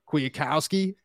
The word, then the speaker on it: Kwiatkowski: Kwiatkowski. – Kwiatkowski out of Bethel Park, Steel City hometown kid.